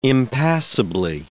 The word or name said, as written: impassibly